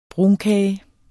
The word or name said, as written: brunkage